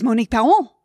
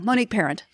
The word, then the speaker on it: Monique Parent: And when I was in Paris, I had the French correct me on the pronunciation of my name. – Oh, and what did they say? – As only the French would do. No, Monique Paon. And I'm like, no, Monique Parent.